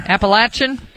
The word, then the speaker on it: Appalachian: Appalachian? It's latch, like a latch.